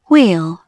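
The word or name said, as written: wheel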